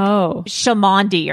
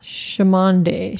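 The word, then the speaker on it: Cholmondeley: It turns out that the Marquis, but I heard that it's pronounced something less classy than that, like Chamondi or something like that. – Chamondi.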